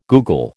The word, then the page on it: googol: American English